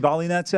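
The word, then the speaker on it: Volynets: By the way, the greatest name in tennis is playing tonight, an American, Katie Bo- Bolinets. Mm. Volley, it's actually Volley Nets, actually.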